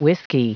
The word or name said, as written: whiskey